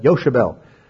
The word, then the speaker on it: Yochebed: That's how they pronounce it. You know, I said Jochebed, all right, but Yochebed.